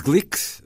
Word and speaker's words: Glück: Agora, Louise Glick, vamos adotar este, esta pronúncia porque não é.